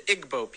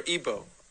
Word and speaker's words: Igbo: What's happening is in Nigeria, you have the Igbo people, or Igbo, pronounced either way.